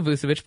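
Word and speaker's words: Vučević: Vucevic, or however you pronounce his name, Trey Young. That would be cool. – Vucevic. Nikola Vucevic, fight on.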